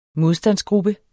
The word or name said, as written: modstandsgruppe